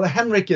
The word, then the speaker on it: Henrik: Well, Henrik is easy. It's Henrik with a K.